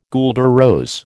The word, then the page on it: guelder rose: American English